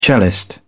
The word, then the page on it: cellist: British English